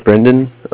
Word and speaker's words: Brendan: So my name is Brendan. That's spelled B-R-E-N-D-A-N.